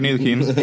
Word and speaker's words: Ntilikina: Frank, I don't know how the fuck he pronounced his last name. – Frank Ntilikina and Trey Burke right now.